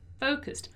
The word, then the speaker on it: focused: Let's pay attention here to the pronunciation of focused. Okay, focused. Once again, that simple pass of regular verbs thing, right? So focused.